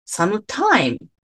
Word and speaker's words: sometime: それぞれ他の単語とかフレーズに言い換えることもできるんですけど、 sometime と sometime と sometimes は副詞なんですけど、 sometime は名詞になるというね。 – ごめんなさい。全部一緒に聞こえるんですけど。 – そう、全部一緒に聞こえるじゃん。わかるわかる。一個ずつ見ていきますよ。 – はい、お願いいたします。 – では最初の方にイントネーションがある sometime。